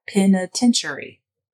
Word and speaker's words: penitentiary: But then someone commented, I think on YouTube, and they were like, penitentiary has 5 syllables. And I was like, yeah, penitentiary. And that's what made me go, well, what did I say?